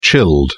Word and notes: chilled: British English

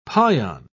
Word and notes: pion: British English

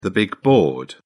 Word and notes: big board: British English

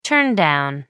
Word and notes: turndown: American English

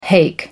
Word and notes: hake: American English

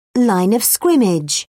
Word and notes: line of scrimmage: British English

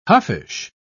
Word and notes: huffish: British English

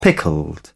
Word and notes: pickled: British English

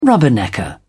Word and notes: rubbernecker: British English

